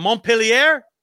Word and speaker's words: Montpellier: He was born in a little town of, I know I'm going to say it wrong because I don't speak French, but Montpellier. – I believe that's perfect pronunciation.